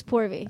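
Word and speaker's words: Purvi: It's P-U-R-V-I is how it's spelled. So that I mean, it looks like pervy, and that doesn't sound complimentary calling someone.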